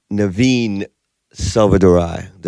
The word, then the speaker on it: Naveen Selvadurai: I'm in here with my homeboy, Naveen Selvadurai. Did I pronounce that right? – That is correct.